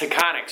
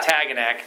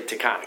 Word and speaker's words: Taconic: How do you, that well, that's Taconic. Sorry, I call it yeah. Taganak, but it's actually Taconic.